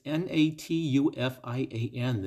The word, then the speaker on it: Natufian: Let me let me say that again and spell it. It's N A T U F I A N.